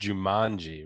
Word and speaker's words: Jumanji: You know it's pronounced Jumanji, right? – All right, whatever.